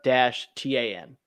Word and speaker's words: Surtain: How to pronounce Patrick Surtain? – Surtain. – Patrick Surtain pronunciation. Surtain. So I guess according to this, Surtain.